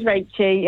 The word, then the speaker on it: Rachie: It's not Rachie, it's Rachie, as in Rachel. – It is Rachie, yeah.